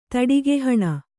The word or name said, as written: ತಡಿಗೆಹಣ